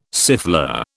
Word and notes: siffleur: British English

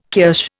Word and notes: kirsch: Indian

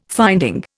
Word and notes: finding: Indian

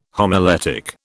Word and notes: homiletic: British English

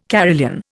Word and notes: carillon: Indian

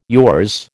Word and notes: yours: American English

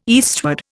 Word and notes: eastward: Indian

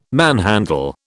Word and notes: manhandle: British English

